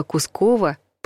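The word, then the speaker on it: Кусково: А вот Кускова надо произносить с ударением на втором слоге.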